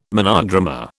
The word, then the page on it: monodrama: British English